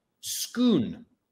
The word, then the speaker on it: Scone: Inside the chair is the stone of Scoon, which is spelt scone or scone. We now have a third pronunciation, Scoon, which is the sacred slab of sandstone on which Scottish kings were crowned.